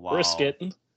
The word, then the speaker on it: Brissett: Next, we're going to go to Jacoby Brissett, who's starting for the Browns right now. – Brissett.